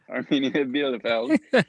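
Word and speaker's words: Arminia Bielefeld: We're looking at Armenia Bielefeld. Is that how you say it? – Yeah, that's perfect. – Okay, Armenia Bielefeld against Borussia.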